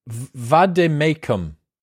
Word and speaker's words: vade mecum: What is a vade mecum? – A vade mecum is a Latin.